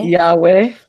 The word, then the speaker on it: Yahweh: but it's Yahweh. – Yahweh? – Yes. Yes. Mm-hmm. And so Yah is spelled Y-A-W.